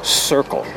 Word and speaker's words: Cirkul: Anyway, so my son, my 16-year-old, has turned me on to a product called Circle, and that is spelled C-I-R-K-U-L.